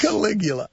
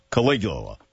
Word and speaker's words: Caligula: Caligula. – Caligula.